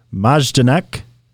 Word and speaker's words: Majdanek: Soldiers were seen marching with people being loaded onto a train and overheard images of Majdanek concentration camp in occupied Poland appeared. Amanda, help me out with that pronunciation. – I think that was please. – Pretty good, actually.